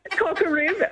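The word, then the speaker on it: Kokaribb: Did you say Cocker Rib? – Cocker Rib. I'll spell it for everybody at home. It's K-O-K-A-R-I-B-B.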